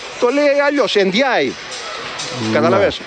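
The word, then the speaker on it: Ndiaye: Όταν ήρθε το πρώτο που τον ρώτησαν, πες μας πώς προφέρεται το όνομά σου. – Ναι. – Και είπε Εμπάι Εντζάι.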